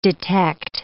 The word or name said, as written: detect